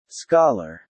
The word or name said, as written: scholar